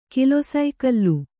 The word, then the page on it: ಕಿಲೊಸೈಕಲ್ಲು: kilosaikallu